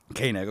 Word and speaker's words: Koenig: Koenig.